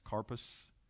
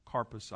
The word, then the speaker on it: karpos: Now, again, I'll remind you, K-A-R-P-O-S, carpus. It's not a long O. It sounds like to me in the develop, as I looked at it, carpus, I think, is the best way to say it.